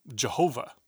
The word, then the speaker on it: Jehovah: By the way, in German, the letters get translated as JHVH, from which we derive the pronunciation Jehovah, as in Jehovah's Witness.